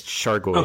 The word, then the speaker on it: Chargois: I'm pretty sure it's Chargois. – Okay, Chargois, yeah.